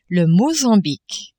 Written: Mozambique

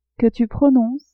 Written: que tu prononces

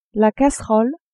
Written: casserole

